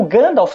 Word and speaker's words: Gandalf: Por exemplo, algumas palavras nas línguas élficas, o F tem um som levemente de V. E o Gandalf, que tem F no final, não tem V.